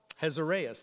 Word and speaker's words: Ahasuerus: His Hebrew name is Anareus. I didn't pronounce that correct. Hazareus, thank you.